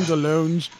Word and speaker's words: DeLonge: Tom DeLonge's actual pronunciation of his name is Tom DeLonge. – DeLonge. – Yeah, it's actually French.